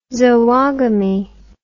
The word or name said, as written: zoogamy